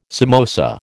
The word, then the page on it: samosa: American English